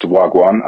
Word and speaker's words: Wagwan: Wagwan is a Jamaican slang, and it is pronounced Wagwan. I know when it's spelt, it's pronounced differently, or it looks different, but that's how you pronounce it.